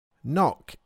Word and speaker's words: knock: Knock is worth spelling because there's a silent letter. It's spelt K-N-O-C-K. The first K is silent.